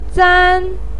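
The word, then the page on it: zan: tone 1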